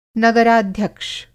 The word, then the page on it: नगराध्यक्ष: Hindi